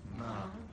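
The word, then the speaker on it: Ma (מה): Ma.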